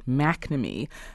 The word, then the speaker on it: McNamee: I think I should mention that a couple of us mispronounced the name of Roger Clemens' trainer. It's Brian McNamee. Sorry for that error.